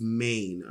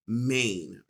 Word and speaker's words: mien: So the word of the week this week is Maine, okay? It's spelled M I E N, Maine.